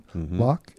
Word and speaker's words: Loch: So I have a website, which can be found through my name, which is spelled L-O-C-H. This is my first name, Locke, and then K-E-L-L-Y.